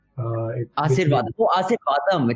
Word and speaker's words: Asirvatham: We're going to have a conversation with Varun Thomas and James Asirvatham. Did I pronounce that correctly? – It's Asirvadam. – Asirvadam. It's like Ashirvad.